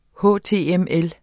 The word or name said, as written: html